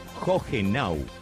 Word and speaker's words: Hohenau: Me refiero precisamente a Hohenau o Hohenau, como me dijeron de Alemania, que se pronuncia así, de esa manera, que significa pradera alta.